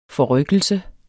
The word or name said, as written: forrykkelse